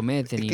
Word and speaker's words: Metheny: ¿Se pronuncia así? ¿Metheny? – Sí. Digo, Metheny, le dicen.